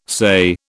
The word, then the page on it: say: British English